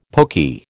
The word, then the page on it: poky: American English